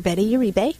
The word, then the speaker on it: Betty Uribe: it's Dr. Betty Uribe. – How do you spell that? – D-R-B-E-T-T-Y-U-R-I-B-E.